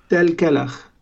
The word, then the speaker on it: Tel Kelach: So what about what happened in Tel Kelach? Am I pronouncing that correctly? It was in April? – Yes, Tel Kelach.